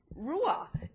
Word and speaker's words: ruah: And the word for joyful is ruah. And it sounds like hoorah.